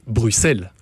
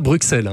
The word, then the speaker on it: Bruxelles: Et alors, on vous remercie infiniment, puisque vous prononcez Bruxelles. On ne dit pas Bruxelles. Hein. – Ouais. Heureusement qu'on n'a pas dit Bruxelles.